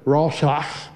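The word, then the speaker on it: Roshach (רצח): If anybody had Spanish, you know you have to roll your R's. Well, in the Hebrew word, it was a roshach, and then you clear your throat. That's kind of how you say it.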